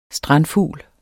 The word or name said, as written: strandfugl